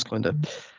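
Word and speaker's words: Klendathu: Is that pronounced? Yes, it's Clendau.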